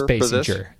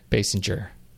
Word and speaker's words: Basinger: It's Basinger. Basinger. Just say it like it needs to be said.